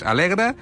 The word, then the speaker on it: Allegre: Alegre, però escrit Allegre, per si algú vol buscar-ho per internet. Té dues L's, però per algun misteri del màrqueting es pronuncia només una. És Alegre, i que més que forma un complexe amb uns apartaments que es diuen Vitamagna.